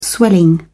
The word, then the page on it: swelling: British English